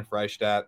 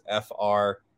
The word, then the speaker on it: Freishtat: It's Justin Freishtat. Last name's tough, so I'll spell it. It's Freishtat.